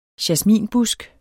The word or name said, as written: jasminbusk